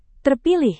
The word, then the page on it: terpilih: Indonesian